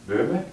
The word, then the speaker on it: Böhme: Well, I think Blake gets it from Swedenborg and Bowen, however you pronounce it, Jacob Böhme? – Yeah, that's it.